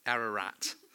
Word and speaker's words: Ararat: How did you say it earlier, Kerry? Ararat.